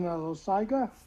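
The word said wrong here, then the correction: Loáisiga: Loisica. – Loisica. – Spell? – L-O-A.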